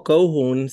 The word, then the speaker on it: Kouhun: Claro, de episodio 2, yo busqué y se ya, no sé cómo pronunciarlo, es Cojuns, co, Cojuns, no, no estoy claro en la pronunciación. – Eh. – Es como Kohun, se escribe K-O-U-H-U-N.